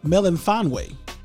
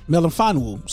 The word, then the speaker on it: Melifonwu: I'm a really big fan of Afetu Melifonwu. Melifonwu. Sorry, I said it wrong.